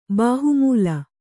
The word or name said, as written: ಬಾಹುಮೂಲ